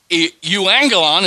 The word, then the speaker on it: euangelion: This is the Greek word euangelion.